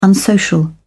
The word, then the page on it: unsocial: British English